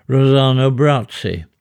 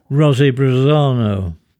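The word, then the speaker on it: Rossano Brazzi: I watch Three Coins in the Fountain and remember an Italian actor who had a brief vogue in Hollywood in the 1950s, Rosano Brazzi. Notably in our family, because he was always mispronounced by Auntie Kathleen as Rosy Brazzano.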